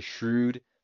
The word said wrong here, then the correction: Shroud: but no, I think it's Shroud now, right?